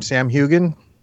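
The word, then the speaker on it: Sam Heughan: I think, and I probably butchered his name, Sam Hugan, Outlander. – Okay. Sounds good to me. – Yeah, I think you actually got that one right.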